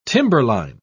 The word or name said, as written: timberline